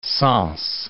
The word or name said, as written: Sens